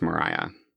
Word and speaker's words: Maria: It's spelled Maria, so I'm probably going to say it Maria a lot. Okay. But I believe it was pronounced Mariah.